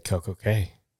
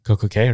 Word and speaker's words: CocoCay: Because K rhymes with day. Yeah. So they say, the perfect day at CocoCay. But I guess the Bahamas dialect pronounces C-A-Y, which an American might read as K. It's pronounced as key. But it doesn't rhyme with perfect day.